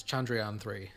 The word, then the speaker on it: Chandrayaan: It's Chandrayaan 3.